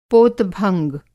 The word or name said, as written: पोत-भंग